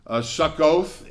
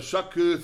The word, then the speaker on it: Succoth: They crossed over the Jordan to Succoth in Gad. I got a long O symbol in my Bible. Makes it a little harder to say Succoth.